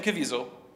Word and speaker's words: Caviezel: Caviezel? Yeah, Jim Caviezel.